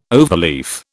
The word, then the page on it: overleaf: British English